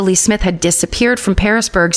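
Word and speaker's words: Pearisburg: but it's like the fruit pear, not Paris, Paris, France, but Parisburg.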